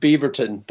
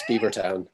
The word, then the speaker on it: Beavertown: I'm going to pronounce it Beaverton, but it's Beavertown.